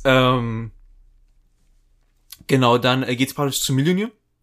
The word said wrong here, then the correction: Mjölnir: Ich kann diesen Namen nicht aussprechen. – Mjölnir. Mjölnir? – Mjölnir. Mjölnir. Mjölnir. Mjölnir. – Ja, ungefähr so.